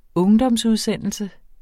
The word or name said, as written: ungdomsudsendelse